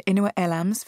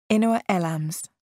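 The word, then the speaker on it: Inua Ellams: My thanks, of course, to Inua Elams for joining us, and also to our student readers in Namibia.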